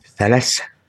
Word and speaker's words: Sales: Francisco de Sales. S-A-L-E-S, or is it Salas? I don't know. – Salas.